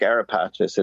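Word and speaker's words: Ara Pacis: Some people try to pronounce it AraPacis or AraPacis. To me, I just like AraPacis. It just, you know, grabs you. – It's got a flow to it. – Well, it's the Italian pronunciation.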